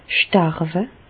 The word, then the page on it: starwe: Bas-Rhin; Reichshoffen